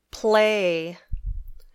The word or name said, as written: play